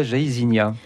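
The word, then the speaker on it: Jaÿsinia: C'est le fameux jardin de la Jaïzinia. – Très bien d'avoir prononcé la Jaïzinia parce que c'est un piège pour tous ceux qui viennent à Samoin parce qu'on attend toutes sortes de prononciations et ce nom vient de Madame Jay.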